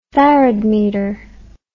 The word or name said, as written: faradmeter